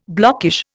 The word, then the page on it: blockish: Indian